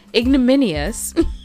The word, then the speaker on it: ignominious: So, for anyone who was unclear and didn't look it up, ignominious, which I think I'm pronouncing correctly. Ignominious. Ignominious. Ignominious. So I now know the correct pronunciation, ignominious.